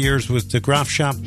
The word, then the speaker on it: Graafschap: The Graafschap. – There you go. – The Graafschap.